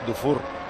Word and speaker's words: Dufour: Tiene misma pronunciación que Stefan Dufour, el jugador aquel que era tan bueno en el Standard de Lieja.